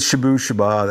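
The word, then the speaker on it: Shabooh Shoobah: Was that with Shabu Shabu or was it later? – With Shabu Shaba, that's how you pronounce that.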